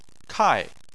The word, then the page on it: kai4: chinese